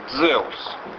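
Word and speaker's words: Дзеус (Ζεύς): Дело в том, что в архаичном греческом языке и слово «теос», и слово «зевс» произносилось одинаково. «Дзеус».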